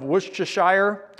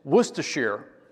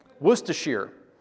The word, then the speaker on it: Worcestershire: I grew up pronouncing it some version of Worcestershire, but I looked it up and it's Worcestershire. Worcestershire.